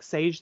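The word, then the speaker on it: Šejić: I think he says it's pronounced more like Sage than Sajic because it's spelled like Sajic, but pronounced Sage.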